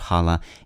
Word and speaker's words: challah: The initial ch of challah is frequently pronounced as a velar fricative, like the ch in the German word Buch or the Scottish English loch.